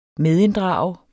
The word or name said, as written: medinddrage